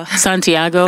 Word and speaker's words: Santiago: So Santiago.